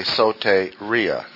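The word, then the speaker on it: soteria (σωτηρία): The verb form is sozo, transliterated S-O-D-Z. Z, that sound. Sozo. Or soteria. Long A, soteria. Or we've already done a study on it, the other word, soter.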